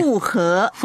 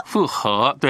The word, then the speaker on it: 复活: 所以这个应当怎么念？ – 复活。 – 复活，对对。和平的和。